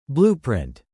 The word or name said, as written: blueprint